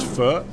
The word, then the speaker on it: pho: And I just want to give a shout-out to Anthony Bourdain, who, for the first time on television I've ever seen, pronounced faux the proper way, which is pho.